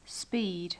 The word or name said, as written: speed